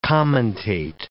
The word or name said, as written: commentate